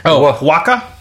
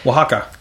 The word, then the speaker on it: Oaxaca: Oaxaca. Oaxaca.